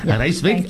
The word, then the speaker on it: Rijswijk: Rijswijk.